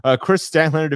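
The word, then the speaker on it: Kris Statlander: Chris Statlander defeated Julia Hart.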